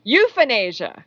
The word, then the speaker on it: euphenasia: All right, the second word of nine in a row is euphenasia. Okay, so this is not euthanasia.